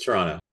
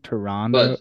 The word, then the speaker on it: Toronto: Well, if you're American, we say it with a D, right? Isn't that the thing? Toronto? But Toronto? – Toronto.